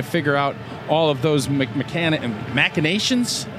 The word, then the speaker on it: machinations: It's machinations, yeah. But it's spelled like machine, and it always gets a little tricky.